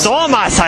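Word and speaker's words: Störmers: Ja, hallo, ich befinde mich hier sozusagen am Stand von Dieselstormers. – Dieselstormers. – Stormers heißt es. – Das ist ein Metal-Umlaut.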